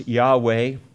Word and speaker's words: Yahweh: which we pronounce Yahweh or Jehovah,